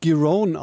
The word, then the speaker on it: Giron: Ja, er spricht sich Giron aus tatsächlich und